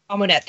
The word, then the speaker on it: Amunet: Amunet, thank you.